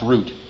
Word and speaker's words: Ruth: In Swedish, it's pronounced root.